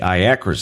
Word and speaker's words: IACRA: Czech rides grind to a halt when the IACRA system coughs.